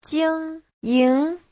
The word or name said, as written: jing1ying2